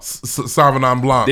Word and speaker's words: Sauvignon Blanc: Sauvignon Blanc.